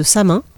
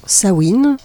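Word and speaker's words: Samhain: vous avez tout d'abord un groupe de discussion autour de la fête de Samin, qui se prononce apparemment Sawin euh,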